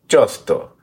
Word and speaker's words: Josto: So I wanted to flip back to some of the Josto scenes, or Josto. Have we figured out how to pronounce his damn name yet? – Josto.